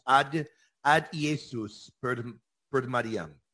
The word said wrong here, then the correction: Ad Jesum per Mariam: Ad Jesum per Mariam.